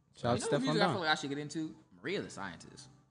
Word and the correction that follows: Mariah the Scientist: She got Mariah. – Mariah the Scientist. Do not disrespect her name ever again.